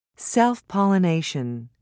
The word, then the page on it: self-pollination: British English